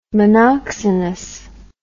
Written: monoxenous

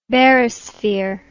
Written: barysphere

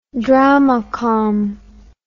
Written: dramacom

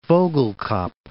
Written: Vogelkop

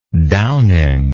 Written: downing

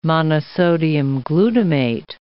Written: monosodium glutamate

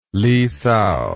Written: Li Sao

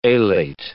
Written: alate

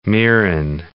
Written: mirin

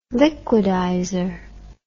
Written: liquidizer

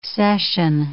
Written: cession

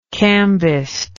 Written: cambist

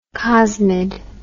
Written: cosmid

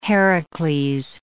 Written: Heracles

